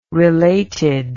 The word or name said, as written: related